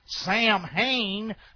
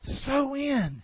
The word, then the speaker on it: Samhain: Now, that's how I'd say it if I'm from North Carolina, Samhain. But it's pronounced So-in.